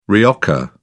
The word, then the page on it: rioja: British English